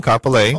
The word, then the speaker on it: Kapolei: So anyway, our guest today is Ilima at Lehano in Kapolei. Is that how you say it? In Kapolei? – Right. It's in Kapolei.